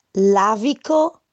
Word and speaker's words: Lavico: The pronunciation is Lavico Nerello Mascalese.